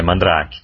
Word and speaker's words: Mandrake: É Mandrake mesmo que pronuncia? – É, Mandrake.